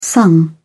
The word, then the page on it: sung: British English